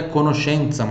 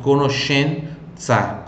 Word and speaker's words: conoscenza: A palavra mais que tem uma pronúncia diferente aqui é conoscenza, mas já expliquei, não é difícil, é SE, som de CHE. Então, conoscenza, conoscenza, repete aí, conoscenza, conoscenza, conoscenza.